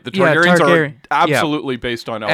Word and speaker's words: Targaryen: The Targaryen, how do you pronounce? Targaryens, yeah. – Targaryens. – Right. Yep.